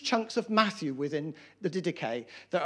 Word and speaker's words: Didache: All right, can everyone say Didache? Didache, Didache. That's how you pronounce it.